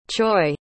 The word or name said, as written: choy